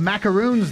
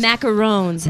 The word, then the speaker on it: macaron: It's not a macaroon, but there's something that's spelled exactly like macaroon that's on my list. – Macarons. – Macaron.